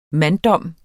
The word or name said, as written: MANDDOMS